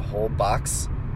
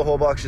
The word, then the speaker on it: Holbox: That's spelled Holbox. So Isla Holbox is what it would sound like if you're a gringo like me. But Isla Holbox is this beautiful little island.